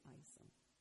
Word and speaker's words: Isom: It's pronounced two different ways, but the family pronounced it Isom. Doesn't look like that, but that's how they said it.